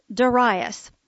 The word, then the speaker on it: Darius: By the way, all my adult life I have pronounced that Darius, but I looked it up during the summer and it is actually pronounced Darius. So, Darius it is.